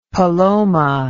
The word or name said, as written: paloma